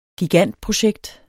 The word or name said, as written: gigantprojekt